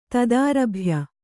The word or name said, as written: ತದಾರಭ್ಯ